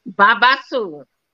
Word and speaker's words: babassu: But I cannot pronounce. Is it Babassu? – Babassu.